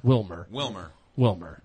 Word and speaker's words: Wilmer Valderrama: Will of how do you say it? Is it Wilmer Valderrama? – Wilmer.